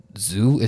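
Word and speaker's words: Xu: I think her name is pronounced Zhu. It's just an X and a U. Pretty sure it's pronounced Zhu.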